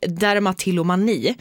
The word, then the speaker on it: dermatillomani: Och dermatillomani. – 10 av 10.